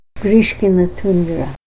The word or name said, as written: Grishkina tundra